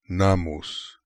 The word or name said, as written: Nammus